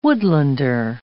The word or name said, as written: woodlander